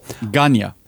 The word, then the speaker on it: Gagne: När Vince McMahon var uppe och skulle kolla om han kunde få köpa AWA av Vern Gagne. Hur fan uttalar man det? Inte så. Ganja. Ja, Vern Ganja.